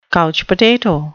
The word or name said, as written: couch potato